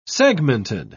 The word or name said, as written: segmented